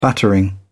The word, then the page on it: battering: British English